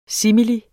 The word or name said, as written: simili